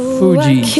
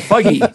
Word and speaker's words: Fugee: F-U-G-E-E. – Fugee. F-U-G-G-E. I'd call that Fuggy. That's really how you spell it? – Well, F-U-G-G-E? Isn't that Fuggy? – No, F-U-G-E-E. – Oh, F-U-G-E-E. – Fugee, like B-G, but foo in front of it? – I'd still call it Fugee.